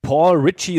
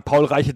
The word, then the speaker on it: Reiche: Genau so wird er auch ausgesprochen. – Ja, genau. Ich habe es vorher nachgeschaut. Ich bin ja nicht bescheuert. Ich habe nämlich jahrelang Paul Reiche III gesagt, bis mir irgendwann aufgefallen ist, dass es nicht geht.